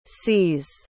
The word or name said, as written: c's